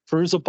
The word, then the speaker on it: Fairuza: And Fairuza Balk, again, I hope I'm pronouncing the name right. – Yeah, I think that's correct.